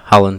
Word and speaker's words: Haaland: Haaland, is that how it's pronounced? – Yeah, Haaland, yeah.